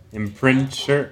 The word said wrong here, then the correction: imprimatur: imprimatur, imprinture. – Ah, no, I didn't know that. No, it's in, imprimatur.